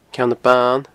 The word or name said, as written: Ceann Ban